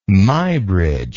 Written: muybridge

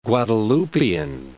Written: Guadeloupean

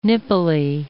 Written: nippily

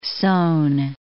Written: Saone

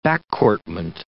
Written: backcourtman

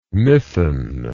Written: mithan

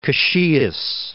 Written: Caxias

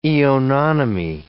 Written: eo nomine

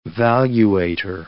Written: valuator

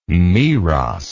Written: meros

